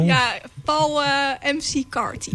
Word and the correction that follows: McCartney: Ik weet nog steeds niet hoe je het moet uitspreken. – Paul McCartney. Nee, Paul McCartney.